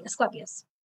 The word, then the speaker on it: Asclepius: So anyways, a fucus, his Greek name is Asclepius. Am I pronouncing it correctly? Asclepius. – Yeah. Mm-hmm.